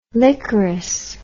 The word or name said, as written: licorous